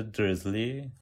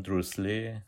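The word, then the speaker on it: Dursley: حسین برای بریتانیاییشو نوشته درزلی تلفظ آمریکایی همون دروسلی که خب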